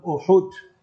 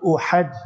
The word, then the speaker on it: Uhud: Third year of the Hijrah we have, you must remember, in the month of Shawwal, the battle of Uhud, not Uhad, learn to pronounce properly.